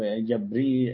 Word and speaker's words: جبريل: جبريل أو جبريل أو ميكائيل أو جبرائيل، يعني لا تنطق جبريل وجبرائيل على فكرة، هذا صحيح وهذا صحيح، بس الشيعة يقولوا جبرائيل على طول ما يقولوش سيدنا جبريل عليه السلام، تمام.